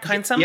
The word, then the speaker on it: Kindsome: Kindsome? – Yep.